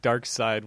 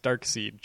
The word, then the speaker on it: Darkseid: If you don't know, it's just because I for the longest time I thought that Dark Side was pronounced Dark Seed just because it's spelled that way. – I've never seen Star Wars. Come on. – No. It's not spelled Dark Side like Pink Floyd's Dark Side of the Moon. It's spelled DarkSeid. So it looks like it would say Dark Seed.